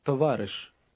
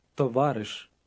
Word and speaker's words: Товариш: Товариш. – It means comrade. Listen and repeat. – Товариш. Товариш.